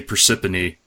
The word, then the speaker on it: Parsippany: I say Parsippany, but sometimes I put a couple extra R's in there.